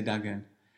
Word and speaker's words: Dagon: Because I've already had it picked up that Dagon, you say Dagon, I say Dagon.